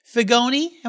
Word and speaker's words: Figoni: Joseph Figoni.